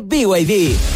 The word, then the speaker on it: BYD: Se escribe BID, pero se dice BYD.